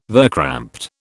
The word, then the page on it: verkrampte: British English